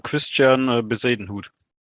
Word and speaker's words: Christian Bezuidenhout: Well, last pick, Christian Bezuidenhout. – Very nice pronunciation.